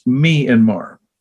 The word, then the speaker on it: Myanmar: Sr.'s son, told me that he had been to a place called, and I'll give you the way that they pronounce it, not the way Siri does or not the way people tell you to, but the actual way the people there pronounce it. It's Myanmar. It's easy for me to remember it this way.